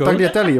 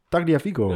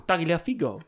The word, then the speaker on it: Tagliafico: Spreek je er nou uit? Tagliafico. Eh, Tagliafico.